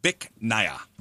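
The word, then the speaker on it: Bích Nga: It's pronounced Bích Nha, B-I-C-H space N-G-A.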